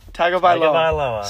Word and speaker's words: Tagovailoa: I'm going to go Tua Tagovailoa. – Tagovailoa. – Sorry, I mispronounced that before.